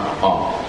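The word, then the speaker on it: maqam (مقام): Apakah betul sholat qobliyah subuh pada maghrib, sholat di Jir Ismail, bukan di Jir Ismail, maqam Ibrahim, maqam Ibrahim, bukan makam, maqam.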